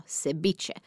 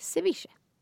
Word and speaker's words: ceviche: Jag vet att det stavas med V. I know. Men om du frågar en mexikan så uttalar man V som B. Det heter alltså ceviche. Men inte med ett hårt B utan ett så här lite sluddigt ceviche.